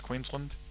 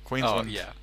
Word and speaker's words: Queensland: born in New Zealand, but he moved at 17 to Queensland, which, by the way, is spelt Queensland, but you don't say it that way. We're going back into that. It's Queensland. – Wait, what? – It's spelt Queensland, but we don't say land. We say Lund.